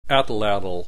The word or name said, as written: atlatl